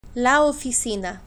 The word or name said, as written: la oficina